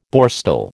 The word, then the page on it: Borstal: American English